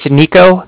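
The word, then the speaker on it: Niko: It's Nico. That's N-I-K-O.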